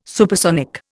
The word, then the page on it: supersonic: Indian